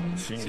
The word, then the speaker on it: Singer: la época la era la Singer. – Y la pronunciación oficial debía ser Singer. – Sí, claro. – Sí. Singer. Sí, es que sí era. Sí. Pero decíamos Singer siempre, sí, en los, sí, montañeritos.